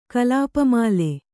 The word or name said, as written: ಕಲಾಪಮಾಲೆ